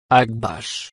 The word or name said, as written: Akbash